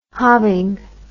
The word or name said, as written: hobbing